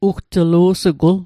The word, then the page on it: ugtlu'sugul: Mi'gmaq